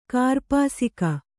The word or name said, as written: ಕೂರ್ಪಾಸಕ